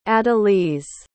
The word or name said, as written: adaliz